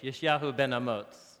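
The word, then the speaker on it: Yeshayahu ben Amoz: Isaiah ben, son of Amos, is how we butcher his name in English. Yishyahu ben Amoz is how you say his name.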